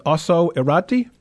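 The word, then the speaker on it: Ossau-Iraty: The next one on the list here, and correct me if with the pronunciation, but it's an Osso Irati? – Yes, you did very well.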